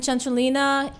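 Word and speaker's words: Centrolina: I love that you say it correctly because Centralina. – Yes, David should be here because you know my Italian sucks. – Yes, so Centro is center, and Lina is sort of an Italian suffix that makes things feminine and small.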